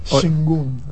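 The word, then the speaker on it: Şengün: Shengun.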